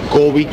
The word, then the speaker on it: Cubby: Kobe Broccoli. – ¿Cómo se escribe Kobe? – C-U-B-B-Y.